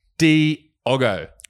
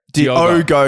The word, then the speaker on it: Diogo: It's D-I-O-G-O, motherfucker. Get it right, all right? – So Diogo. – Yeah. Diogo, you fuck wit. All right. Daniel. Daniel. It's Diogo. Just say it normally, cunt.